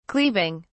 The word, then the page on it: cleaving: British English